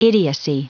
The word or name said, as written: idiocy